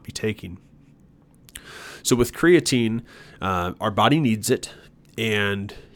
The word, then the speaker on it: creatine: Technically, it's a compound called creatine. Some people pronounce it creatine. Both are correct as far as I'm concerned.